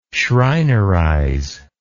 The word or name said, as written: schreinerize